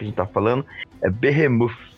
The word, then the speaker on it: Behemoth: Ela tem um nome, uma escrita diferente, mas a pronúncia é essa, Behemoth.